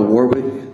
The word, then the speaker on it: Warwick: David Dale Warwick. – Mr. Warwick, can you spell that name for the record? Spell it. – W-A-R-W-I-C-K.